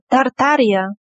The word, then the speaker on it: Tartaria: Tartaria, if I'm pronouncing it right, that is somewhere in modern Russia? – Tartaria.